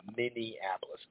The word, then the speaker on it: Minneapolis: Mindianapolis, a lot of people say it. Yeah, you ever go to Indianapolis or Mindianapolis? How do you say that? It's Minneapolis. Anyway, golly, Michael Fox just got robbed in broad daylight.